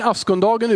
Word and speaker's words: askonsdagen: Ha, askondagen eller askondagen, askonsdagen eller ja, men askondagen uttalar man det.